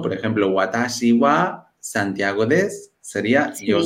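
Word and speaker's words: wa (は): Hemos aprendido cómo funciona el wa, aunque se escribe ha,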